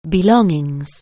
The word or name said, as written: belongings